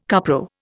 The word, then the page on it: cupro: Indian